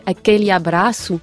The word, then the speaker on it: Aquele Abraço: Si on reprend cette chanson, donc la prononciation exacte, ce serait Aquele Abraço. – Ah oui, vous faites bien.